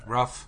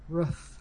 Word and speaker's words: roof: I don't say rough. – I know. – I say roof. – You say like rough. – Hoof. – Hoof. – Right? – Rough. – You say roof. – Roof. – Like rue the day. Roof. – Roof. – And then there's rough, like that sandpaper is rough, but I say it like a horse's hoof.